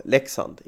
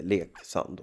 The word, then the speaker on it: Leksand: Men uttalet är ju då Leksand, inte Leksand.